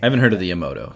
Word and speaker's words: Yamato: the Yamato. Did I say that right? – Yamato, thank you.